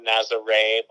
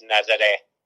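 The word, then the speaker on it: Nazaré: Like, foreigners, Americans call it Nazare, but it's Nazare.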